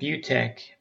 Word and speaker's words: Beautec: So I recommend a product called Butec by EasyCare. That's spelled B-E-A-U-T-E-C by EasyCare.